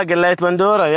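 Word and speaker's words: Galayet Bandora: You cooked galliat bandura. Right? This is how you say it? – Ah, galliat bandura, yeah, right.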